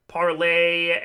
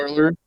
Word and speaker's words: Parler: Parler? – Yeah, spelled Parler, pronounced Parlay. – Oh, is it really? – Yes, it is. For whatever fucking reason, it's pronounced Parlay.